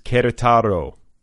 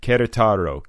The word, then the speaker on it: Querétaro: This episode of Other People is sponsored by Other Voices Querétaro. I think that's how you pronounce it. Querétaro. Q-U-E-R-E-T-A-R-O.